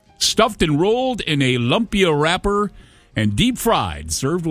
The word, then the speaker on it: Lumpia: Lumpia? L-U-M-P-I-A. Am I saying that correctly? – Lumpia? – Lumpia City. Sure.